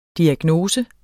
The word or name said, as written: diagnose